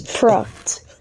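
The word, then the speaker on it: Pracht: Well, read what it says. Proct. okay, because it says in the notes like, like rock. Okay, it's like so, proctor.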